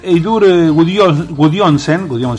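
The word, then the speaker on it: Eiður Guðjohnsen: DNI también se hizo tendencia porque ayer Eidur Gudjonsson, así se pronuncia,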